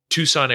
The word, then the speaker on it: Tucson: And there were two places that I felt extremely comfortable in: Texas and uh, Tucson, Arizona, or Tucson, as we as Victoria and I were calling it when we were there because it's spelled weird.